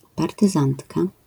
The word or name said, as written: partyzantka